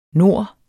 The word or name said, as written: nor